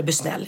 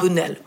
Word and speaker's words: Busnell: Heter det Bunell eller Busnell? Jag tror att det heter Bunell. Ja, Typ, ja. – Det stavas Busnell, men jag tror det uttalas ja. Bunnell.